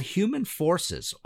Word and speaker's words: Human Forces: It's spelled Who, as in the band The Who Man For Says. But you're supposed to pronounce it Human Forces.